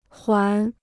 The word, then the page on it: 缓: huǎn